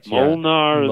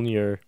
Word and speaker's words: Mjolnir: Mjolnir. Yeah. Mol- Mjolnir. – Yeah, that's that's authentic. You understand? I mean, as authentic as it can be, considering there's no such person as Thor, as far as we know.